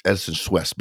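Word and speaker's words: Edison Chouest: So Allison is actually the vice president of human resources at Edison Schaust offshore. Did I butcher that? – Yeah, you did butcher it. It's Edison Schwest, but pretty close. – Schwest. Okay.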